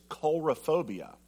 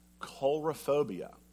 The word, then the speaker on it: coulrophobia: This other one, I had to look up the pronunciation of it. It is coulrophobia. Coulrophobia.